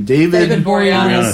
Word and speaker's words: Boreanaz: David Boreanaz. – Boreanaz.